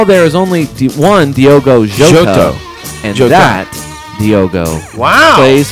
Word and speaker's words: Jota: How to say Diogo Jota. It's Diogo. Jota. – Jota. – Yes.